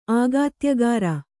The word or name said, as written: ಆಗಾತ್ಯಗಾರ